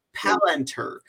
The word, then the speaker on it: Palantir: In 2014, Peter Thiel did an interview with Bloomberg Palantir. Sorry, pronounced that. Yeah. Palantir.